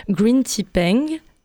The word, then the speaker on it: Green Tea Peng: C'est Gritty Peng. Excusez-moi, mais n'importe quoi cette prononciation. Green Tea Peng, Sata de l'album Mad Made, et c'est tout récent dans vos oreilles.